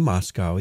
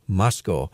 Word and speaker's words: Moscow: And notice how he said Moscow. He did not say Moscow. He pronounced it the way the Idahoans do, Moscow.